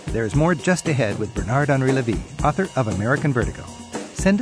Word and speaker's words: Bernard-Henri Lévy: I'm talking with Bernard-Henri Lévy. I'm working on my French pronunciation. Bernard-Henri Lévy.